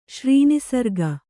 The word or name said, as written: ಶ್ರೀನಿಸರ್ಗ